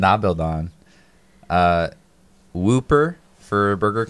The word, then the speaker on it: Dahbeldahn: but it's Da Bil Don. It's another KFC ripoff, but it's spelled D-A-H-B-E-L-D-A-H-N.